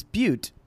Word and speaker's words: Butte: At Butte, they were wary. – I pronounce that butt. – It's Butte, and there's a couple times where it's funnier to say butt.